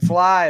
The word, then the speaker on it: Flythe: and Fly is my last name, mm-hmm. and it's spelled T-H-E, so it's a silent